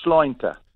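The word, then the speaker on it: Sláinte: How do you say it? – That's right. Well, it's Schleunter. – Okay. – Slointe.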